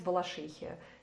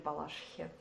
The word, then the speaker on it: Балашихе: Ну, например, воинская часть в Балашихе. Или в Балашихе. Куда ударение поставишь.